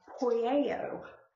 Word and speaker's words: poieo: This one, it is poieo. And here's how you spell that. It's P-O-I-E-O. P-O-I-E-O.